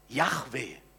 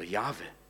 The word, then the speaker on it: Jahwe: Und der Name heißt in Buchstaben ausgedrückt, und ich buchstabiere es mal äh, hebräisch, J, He, Waf, He, Yahweh ausgesprochen. Oder Jahwe.